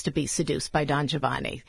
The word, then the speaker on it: Giovanni: But the I just makes the G soft. If the I wasn't there, it would be Giovanni. So the I makes it Giovanni, Giovanni.